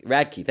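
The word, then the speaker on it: Radtke: In Seek You, wide-ranging exploration of our lives and public selves, the author Radke, I believe it's pronounced. – Radke? – Radke, thank you.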